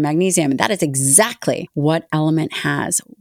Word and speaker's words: LMNT: It sounds like Element, but it's spelled L-M-N-T.